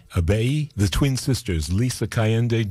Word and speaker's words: Ibeyi: And Lisa in France, known as Abeyi, that's spelled I-B-E-Y-I, Abeyi.